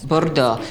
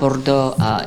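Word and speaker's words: Bordò: Bordeaux. So it's not Bordeaux, in France, but it's called Bordeaux, B-O-R-D-O with an accent.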